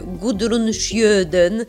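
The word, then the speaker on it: Gudrun Sjödén: W biegu wdziałam na siebie swoją jedną z dziesięciu ulubionych sukienek e, Gudrun Schjöden, tak to należy wymawiać, koleżanka.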